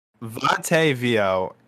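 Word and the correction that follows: Yateveo: is not veal. Yatevio. Sorry, my mispronunciation.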